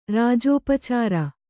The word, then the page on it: ರಾಜೋಪಚಾರ: kannada